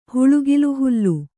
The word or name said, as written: ಹುಳುಗಿಲುಹುಲ್ಲು